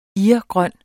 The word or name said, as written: irgrøn